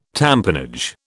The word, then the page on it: tamponage: British English